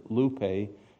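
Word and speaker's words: lupe (λύπη): You don't need to know the Greek letters because there it is in English letters, lupe. And it even tells you how to pronounce it, lupe.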